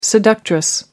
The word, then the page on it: seductress: American English